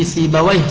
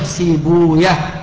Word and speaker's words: سيبويه: لكن المحدثون يقولون زنجوية وحمدوية وراهوية، ويقولون في سيبويه سيبوية، والسبب في ذلك أن أهلها ينطقونها هكذا سيبوية وراهوية وحمدوية وزنجوية وهكذا، فهم مبني عندهم الأمر على ما؟ على السماع. نعم. وأهل اللغة على القياس يقولون زنجويه وخالويه وحمدويه وهكذا،